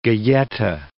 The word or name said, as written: galleta